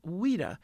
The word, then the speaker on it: Ouida: It's spelled O-U-I-D-A, Ouida.